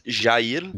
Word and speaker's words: Jair: It's Jair.